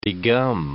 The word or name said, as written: degum